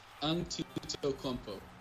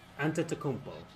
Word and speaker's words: Antetokounmpo: Yeah, it's Antetokounmpo. – Antetokounmpo. – Antetokounmpo. – Like five syllables.